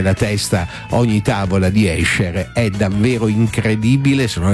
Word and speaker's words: Escher: Poi festeggiamo un artista che ha fatto impazzire il nostro cervello, non so se avete presente, si chiama Escher. È questa la pronuncia esatta, attenzione, Escher, perché si pronuncia come la SC come pesce, alcuni dicono Esche, altri Escher, Escher.